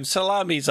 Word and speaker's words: Salamis: Once upon a time, Salami Island, I think. Is that how you say it? – Why not? Why not? How is it spelled? – S-A-L-A-M-I-S. – Well, I would say Salamis.